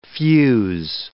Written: fuse

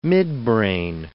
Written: midbrain

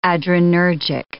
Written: adrenergic

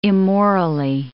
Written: immorally

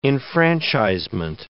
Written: enfranchisement

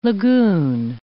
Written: lagoon

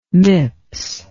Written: MIPS

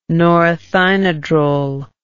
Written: norethynodrel

